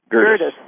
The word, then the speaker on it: Gerdes: How do you pronounce your last name again? – It's Gurdes. Gerdes. – Okay. – That's all right. – Two syllables.